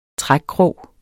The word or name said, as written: trækkrog